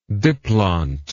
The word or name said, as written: diplont